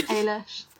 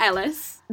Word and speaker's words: Eilish: Much like Eilish in Brooklyn. – Eilish. Is she? – Not Ellis.